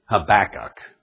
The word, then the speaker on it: Habakkuk: I take the southern way of saying it. Habakkuk. Okay. Maybe that's... Anyway, I think that's the way it's pronounced in the King James pronunciation. Anyway, here's what had happened.